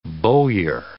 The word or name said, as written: bowyer